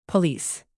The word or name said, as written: police